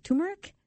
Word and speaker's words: turmeric: That's another one a friend recently was talking about, just said she's been researching, and then she discovered turmeric? – Yes. – Am I saying that right? – Yes.